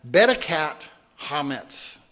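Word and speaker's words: bedikat chametz: Spell that for you. B-E-D-I-K-A-T. So cat with a K instead of a C. Bed-I-cat. Bedekat Hametz. Again, you kind of got that gargled H sound, right? That Hebrew guttural sound.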